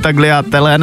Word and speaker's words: tagliatelle: Já jsem tady zmiňoval před chvilkou ty moje milovaný tagliatelle nebo tamto. To se vyslovuje jak, prosím tě? – Tagliatelle. – Tagliatelle, takže G je prostě nezmělý.